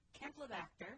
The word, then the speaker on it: campylobacter: Leading chicken producers have stabilized the incidence of salmonella, but spiral-shaped campylobacter, is that the good pronunciation? – Sure.